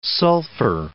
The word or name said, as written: sulfur